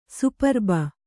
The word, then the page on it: ಸುಪ್ರಭೆ: Kannada